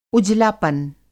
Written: उजलापन

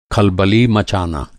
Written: खलबली

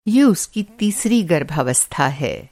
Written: गर्भावस्था

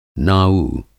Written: नाऊ

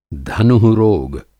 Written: धनु:रोग

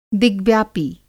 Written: दिग्व्यापी

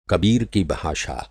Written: कबीर

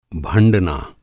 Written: भंड़ना

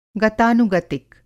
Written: गतानुगतिक

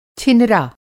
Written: छिनरा

